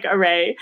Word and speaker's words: Arey: And I'm not going to say it because I'm going to butcher it, but you know, I think the T is silent. And so it kind of sounds like array. – Array. – Yes. Yeah. See, it's beautiful. I could never make it sound that good. I distinctly remember this. I was Googling it like 10:30 at night before I was about to go to sleep. And I was like, oh my God, that rhymes with gray.